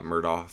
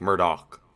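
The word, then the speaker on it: Murdaugh: It was spelled like Doe, like it was Mur and then D-A-U-G-H. – Okay, yeah. So Murdoch. – Murdoch, you would think, but it was like a hard stop.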